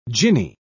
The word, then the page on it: djinni: British English